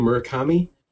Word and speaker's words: Murakami: You know, I've heard things, is that how you pronounce the name, Murakami? – Mm-hmm.